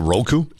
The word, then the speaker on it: Roku: Is it Roku? – Yeah, R-O-K-U is how it's typically spelled.